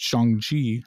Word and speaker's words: Shang-Chi: And there's rumors that Shang-Chi, I said it right that time, will be taking place in this time period too.